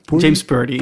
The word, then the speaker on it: Purdy: Of hoe, spreek je dat uit? – James Purdy. – Purdy.